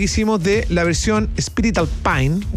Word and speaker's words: Alpine: Pero yo sí, pero lo dicen mal porque la pronunciación real es más Alpine. No como Alpine. – Claro. Alpine, no es anglo. – No, es francés, po.